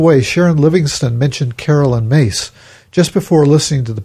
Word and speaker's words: Myss: Miss, M-Y-S-S, is pronounced like Mace.